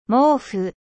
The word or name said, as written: couverture